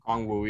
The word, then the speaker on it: Okongwu: Say his last name again. Okongwu. Am I saying it correctly? – Okongwu. Yeah.